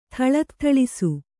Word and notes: ಥಳತ್ಥಳಿಸು: Kannada

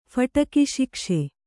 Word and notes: ಫಟಕಿಶಿಕ್ಷೆ: Kannada

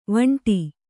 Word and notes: ವಿಂಡೆ: viṇḍe

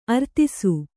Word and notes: ಅರದೇಸಿ: aradēsi

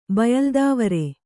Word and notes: ಬಯಲ್ದಾವರೆ: bayaldāvare; Kannada

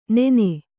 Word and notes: ನೆನೆ: nene

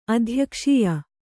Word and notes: ಅಧ್ಯಕ್ಷೀಯ: Kannada

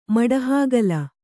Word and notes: ಮಡಹಾಗಲ: maḍa hāgala

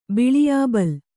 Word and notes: ಬಿಳಿಯಾಬಲ್: biḷiyābal

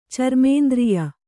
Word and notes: ಚರ್ಮೇಂದ್ರಿಯ: Kannada

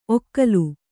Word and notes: ಒಕಲು: Kannada

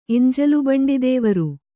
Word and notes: ಎಂಜಲುಬಂಡಿದೇವರು: eñjalubaṇḍidēvaru

